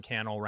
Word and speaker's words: Cannell: And then other than that, I'm Sean Cannell, rhymes with YouTube channel across social media platforms.